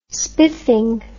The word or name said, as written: spiffing